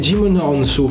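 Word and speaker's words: Djimon Hounsou: Poi, e questo non ho mai capito come si pronuncia, eh, ma... Jim, eh, Jimononsu, credo, Jimononsu. perché la D è muta come in Django.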